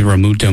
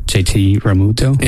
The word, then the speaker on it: Realmuto: And some people are thinking that JT Realmuto might be part of a bigger trade that we make for a starting pitcher. – I like how you say his name. Say it again. – JT Realmuto.